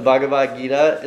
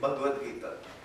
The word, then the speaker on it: Bhagavad Gita: But the Bhagavad Gita is another sacred scripture. Did I say it right? Nope. How would I say it? Help me out. – It's almost correct. Bhagavad Gita.